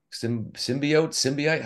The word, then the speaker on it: symbiote: I can't remember who that was, but they basically took a photo of me and gave me the symbiote. Symbiote? How do you say it? – Symbiote, yeah.